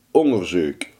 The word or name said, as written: onderzoek